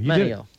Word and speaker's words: Manteo: How do you pronounce that? – Manteo. Manteo. Right on the money.